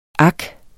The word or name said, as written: ak